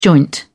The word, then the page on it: joint: British English